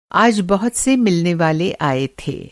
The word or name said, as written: मिलनेवाला